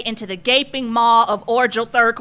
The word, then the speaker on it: Orgelthrek: You said Orgelthrek, but it's pronounced Orgelthrek with a hard G.